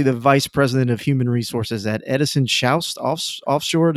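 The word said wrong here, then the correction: Edison Chouest: Did I butcher that, Mark? – Yeah, you did butcher it. It's Edison Schwest, but pretty close. – Schwest. Okay.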